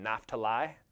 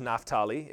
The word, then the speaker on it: Naphtali: Number six is Naphtali, and that is how you pronounce that. I grew up saying Naphtali, but it is Naphtali if you want to do it right.